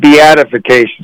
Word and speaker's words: beatification: It's beatification, I believe. – That's the word. Thanks. I was pronouncing it totally wrong.